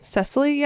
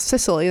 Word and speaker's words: Cecily: Is it Cecily? Is that how you say it? – Cecily, yeah. – Never heard that name. – It's not Sicily. That's like Italy.